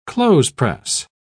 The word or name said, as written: clothespress